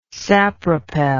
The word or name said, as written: sapropel